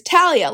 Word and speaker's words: Thalia: It's it's this is such a split and I've seen it and I understand both sides, but in the westernized world that is Talia. Like that is absolutely But the whole point Talia. is that it's pronounced the Greek way.